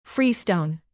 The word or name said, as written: freestone